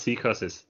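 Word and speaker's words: Psicosis: But yeah, interestingly pronounced C-curses in Spanish.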